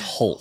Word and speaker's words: Hoult: I think it's Holt.